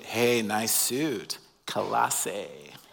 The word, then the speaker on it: Colossae: How do you pronounce the name of this city? And if you're like me, I read it on paper and I'm like, how do I say that word? We seem to have a hard time with it. So to make it easy, think like a Canadian. Colossae.